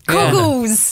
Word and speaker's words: Coggles: I mean, the way Coggles you're saying it makes it sound like you're saying cock and balls. – Yeah. – It's so... – Yeah. – I mean, maybe just pronounce the G.